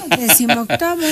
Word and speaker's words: decimoctava: ¿Cómo se dice elegantemente 18ª? La 18ª. Esa es actual. La 15ª. – Decimoctava. – Es que así le dices, ¿no? – Sí, sí, sí.